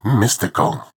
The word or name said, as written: Mystical